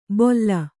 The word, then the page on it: ಬೊಲ್ಲ: bolla